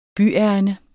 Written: byærinde

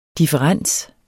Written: differens

